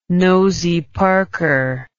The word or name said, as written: nosey parker